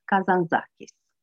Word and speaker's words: Kazantzakis: Acestea fiind spuse, să trecem la Uh, romanul Ascensiunea de Nikos Kazan Elena Lazar ne-a corectat și ne-a spus că nu se spune Kazan așa cum pronunțam până acum, ci Nikos Kazantzakis.